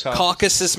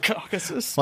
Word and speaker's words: Caucasus: Caucasus Mountains. There you go. – Caucasus.